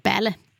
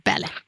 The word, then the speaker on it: Balle: Ja, och Solveig heter, det stavas B-A-L-L-E, men uttalas Belle. Belle.